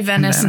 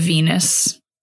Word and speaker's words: Venice: But maybe say Venice instead of Venus. – I did say that wrong.